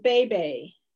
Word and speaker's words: bebe: It's pronounced Bebe. – All right. – Bebe. – Bebe. – Just think of some bebe. Suave Frenchman. Oh, Bebe.